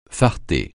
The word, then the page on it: farter: francais